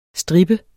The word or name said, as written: stripper